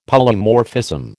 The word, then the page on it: Polymorphism: American English